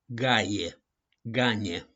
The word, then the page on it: GAIA: GANE